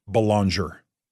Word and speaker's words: Belanger: I can also tell you, right from the Secretary of State himself, he would like his last name pronounced Belanger.